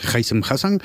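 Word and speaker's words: Haissem Hassan: Y bueno, pues cuando hablabas del Oviedo, el equipo de Haysem Hassan, ya he aprendido a pronunciarlo bien, es Haysem Hassan,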